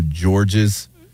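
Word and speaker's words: Georges: Yeah, the report says the running back was is David Gabriel George's. – George. The S is silent. It's a weird one. – Okay, yeah, George with a silent.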